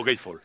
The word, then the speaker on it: Gatefold: El Gatefold, ¿no? ¿Sí? ¿Cómo se dice? – Sí, bien, ya está. – Bien, bien. Abres el Gatefold. – ¿O Gatefold? – Gatefold.